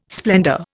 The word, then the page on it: splendour: Indian